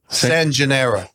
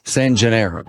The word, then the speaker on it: San Gennaro: San Gennaro? – Feast of San Gennaro. – San Gennaro.